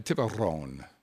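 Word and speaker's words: Tiburón: Tre uh, Tiburon. You must roll your R's.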